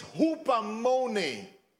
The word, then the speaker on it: hupomone: And the Greek for this word is hupomone. Hupomone. It's spelled H-U-P-O, but it's pronounced as in a basketball hoop.